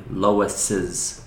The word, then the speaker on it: Lois's: I think also because when you spell it, like if I want to say like Lois's people, you know, Lois's brother, you want to write like Lois apostrophe S. – Mm-hmm. And especially if you just were learning English by reading a lot and not actually speaking or listening as much, you might have read that a lot, names ending with S and just seen apostrophe and not realize you need an extra sound at the end.